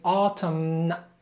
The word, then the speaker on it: autumn: Be careful not to say autumn. The N is silent, so autumn.